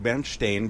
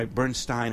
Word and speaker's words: Bernstein: And those Ostjuden, you know, they were Dreck, you know, the Russian Jews wanted to have the German pronunciation in order to have more cachet, and that's why Bernstein insisted that his name was Bernstein, even though as a child everybody known him as Bernstein.